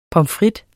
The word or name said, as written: frites